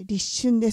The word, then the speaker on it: 立春: 今の期間は先週もお伝えしたようにもう春の節気、 1 年の最初の、ね、節気となりますが、 1 年の始まりの節気ということで、立つ春と書いて立春ですね。